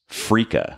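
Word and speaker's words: freekeh: Okay, moving on to the next food, which is Frika.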